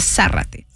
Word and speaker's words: Zárate: Es con tilde, bueno, en español es con tilde la A, entonces Zárate.